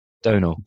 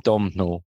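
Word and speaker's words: Domhnall: and I did consult the Irish contingent about how to pronounce his name, and I think you nailed it. Give it to me one more time. – Donal? – Oh, no, sorry. Donal. – Donal? – Donal. Yeah. I kept calling him Domnall.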